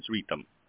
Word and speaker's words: Ritam: My name is Ritam.